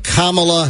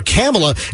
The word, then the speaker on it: Kamala: I could have sworn that it was Kamala rhyming with Pamela. I thought it was Kamala rhyming with Pamela. I heard so many, even newscasters, call her Kamala, and then suddenly it became Kamala. Like comma.